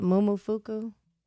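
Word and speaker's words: Momofuku: Mumu Fuku? Yeah. – Whoa. – M-O-M-O. – Fuku. – F-U-K-U.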